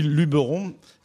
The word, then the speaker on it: Luberon: Et donc on dit Luberon quand on, prononce d'une façon locale, même s'il y a beaucoup de gens qui disent, moi, je m'envie dans le Luberon, mais.